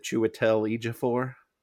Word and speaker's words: Chiwetel Ejiofor: Chiwetel Ejiofor? – Yeah, I'm glad you could pronounce it.